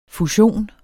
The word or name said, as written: fusion